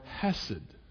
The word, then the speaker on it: chesed: The word is chesed, or hesed.